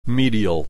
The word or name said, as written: medial